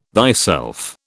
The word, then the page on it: thyself: British English